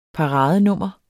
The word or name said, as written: paradenummer